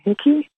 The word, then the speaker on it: Hickey: I'm Marilyn Hickey. Rhymes with sticky.